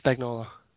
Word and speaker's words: Spagnuolo: And did I say his name right? – Spagnuolo. – Thank you. I almost called him Spicoli.